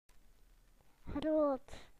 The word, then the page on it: large: British English